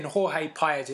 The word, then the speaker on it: Jorge Paez: But yeah, listen, if you go on YouTube and look him up, type in Jorge Paez. It's spelled J-O-R-G-E and Paez is P-A-E-Z, right?